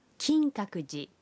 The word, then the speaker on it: 金閣寺: মন্দিরের নামের জাপানি উচ্চারণটি লক্ষ্য করুন কিনকাকুজি